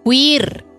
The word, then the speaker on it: queer: ¿Care se escribe? – No, care. – Queer. – ¿Queer? Ya, ahora cuéntame a mí, concha tu madre, con la pronunciación. – Deletréalo. – Q-U-E-E-R. – Queer, po, queer. – Queer. Ya, queer.